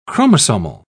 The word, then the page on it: chromosomal: British English